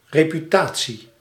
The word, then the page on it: reputatie: /reː.pyˈtaː.tsi/